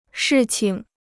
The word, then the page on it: 事情: shì qing